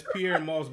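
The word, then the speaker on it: Pyer Moss: It's Pierre Moss, but it's spelled P-Y. – With a Y. With a Y.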